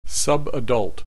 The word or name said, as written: subadult